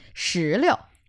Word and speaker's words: 石榴: 石榴. – 石榴. – Uh-huh. 石榴. – 石榴. – One thing about this word is that 石榴, the second character in 石榴 is the light tone. – 石榴.